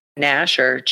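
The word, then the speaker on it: Gnasher: Snasher? That's his name? Snasher? – Nasher, G-N-A-S-H-E-R. I'm assuming the G is silent.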